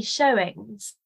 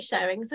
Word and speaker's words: Showings: Their visions particularly come to mind, and is it the shoeings? Is that how you say it? Shoeings? – Oh, so Julian writes it with an E. But I'm not a medieval expert. As far as I know, you just say showings. – Oh, right. – Yeah, but I could be completely wrong. But I say showings, and it's my book, so.